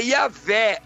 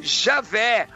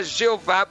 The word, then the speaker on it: Yahvé: Possi possivelmente, a melhor, a melhor tradução, a melhor forma de pronúncia seria Yahvé ou Javé, mas não é errado falar Jeová porque é a forma portuguesada.